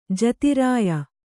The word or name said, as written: ಜತಿರಾಯ